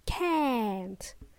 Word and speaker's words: can't: Can't. You can choose whichever you feel comfortable with, okay? And stick with it. Make sure you pronounce the last letter T in this case.